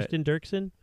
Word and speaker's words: Kirsten Dirksen: How do you say her name? Right. Kirsten Dirksen. – That sounds good.